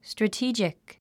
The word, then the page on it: strategic: strətíːdʒik; ストラティー ジク